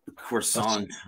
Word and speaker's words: croissant: No, I mean, I've had like a croissant or scone. – And I'm using the American pronunciation because it isn't a croissant that they make.